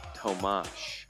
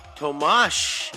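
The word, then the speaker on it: Tomáš: That's why our hammer midfielder, his first name is pronounced Tomash. – Tomash. – There's no H. – Well, you're right. Like there's not. But when your S on your name has that little wedge or Karen above it. – A Karen. – It is pronounced exactly like the way we say SH.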